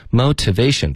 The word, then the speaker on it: motivation: motivation 的特我们会浊化成一个的的音。